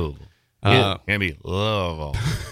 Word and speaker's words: Louisville: I say Louisville. It's not Louisville.